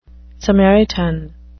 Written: samaritan

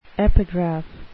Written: epigraph

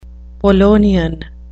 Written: polonian

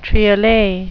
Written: triolet

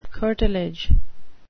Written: curtilage

